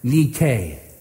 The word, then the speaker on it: Nike: And I'm not trying to be funny here, but the Greek word for victory in 1 John chapter 5, verse 4 is Nike, or pronounced Nike. Nike.